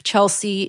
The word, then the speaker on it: Chelsy: It's written down so that it can be pronounced in Spanish. So instead of the EA or EY that you typically find at the end of Chelsy, it's just an SY.